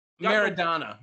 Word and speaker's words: Maradona: Maradona.